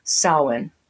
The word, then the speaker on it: Samhain: And we see that on this chart with the word that it's spelled like Samhain, but it's pronounced Samhain because it's Gaelic, and Gaelic isn't ever pronounced like it looks.